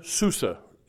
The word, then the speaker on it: Susa: That in those days when the king, Xerxes, sat in the throne of his kingdom, which was in Susa, is how they say it in the modern way,